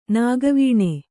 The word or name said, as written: ನಗೆವೆಣ್ಣು